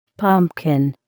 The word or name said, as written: pumpkin